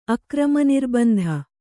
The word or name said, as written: ಅಕ್ರಮನಿರ್ಬಂಧ